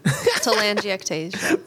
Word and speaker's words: Telangiectasia: How do you say this one? – You got this. Telangiectasia.